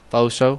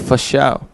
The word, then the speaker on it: fo sho: F-A-S-H-O. – I thought it was F-O-S-H-O. – Faux-show? – Faux-show. – It's not faux. For show. Not faux-show. I say for show.